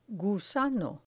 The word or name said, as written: gusano